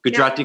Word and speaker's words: Gujarati: Gujarati culture, and, you know, places like Dar es Salaam in Tanzania, where Rina, the main character, is from.